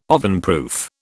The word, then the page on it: ovenproof: British English